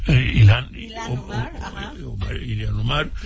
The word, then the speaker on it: Ilhan Omar: Ilhan Omar.